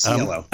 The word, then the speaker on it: Cielo: It's Cielo Drive, right. isn't it? That's yes. I think that's how you pronounce that. – Yes, Cielo.